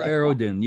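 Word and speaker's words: Erdoğan: Erdogan, yeah. It doesn't look like it sounds.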